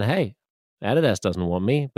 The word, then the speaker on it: Adidas: His Adidas deal, or Adidas, as Americans say, was worth about $1.4 billion.